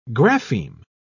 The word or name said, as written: grapheme